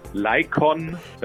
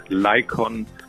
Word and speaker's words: Laiqon: Ja, wenn man ihn ein paar Mal gehört hat, Lycon, dann spricht sich das eigentlich sehr leicht. Lycon. – Es spricht sich sehr leicht, aber für unsere Zuhörer dann, das ist L-A-I-Q-O-N. Also ja. Lycon gesprochen.